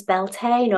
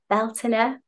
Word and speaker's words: Beltane: This is a Sabbat known as Beltane or Beltane. There are a few different pronunciations, a bit like Sanskrit.